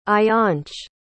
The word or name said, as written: ayaansh